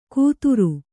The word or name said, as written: ಕುತ್ತುರು